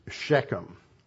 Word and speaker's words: Shechem: But at that time, it was Shechem, as it's pronounced in Hebrew.